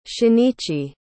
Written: shinichi

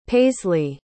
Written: paisleigh

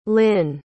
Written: lin

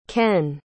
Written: kenn

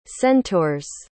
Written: centaurs

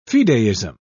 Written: fideism

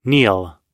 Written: neal

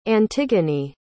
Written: antigone

